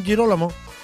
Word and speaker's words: Girolamo: San Jerónimo, Santa Sofía y San Girolamo. Girolamo, sí, San Girolamo. Justamente, bien pronunciado, bandita, bien.